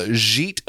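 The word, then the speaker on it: Jeet: Yeet Raval. It's at soft J.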